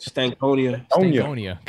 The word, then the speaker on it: Stankonia: Stankonia.